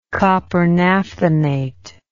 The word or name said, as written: copper naphthenate